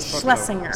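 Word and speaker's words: Schlesinger: I always Shlesinger.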